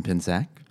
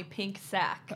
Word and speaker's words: Pincsak: This is episode 6, and I am so excited to welcome Allison Pinsack. Did I say that right? Yeah. Have I been saying it right? – Mm-hmm. They used to call me Pink Sack.